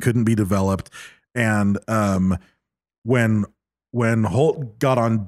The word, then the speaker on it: Holt: So at this point that morning, apparently Lieutenant Colonel Holt, they kept saying Holt. – It's Holt. – But it's pronounced, it's spelled Halt, H-A-L-T. – H-A-L-T, Halt.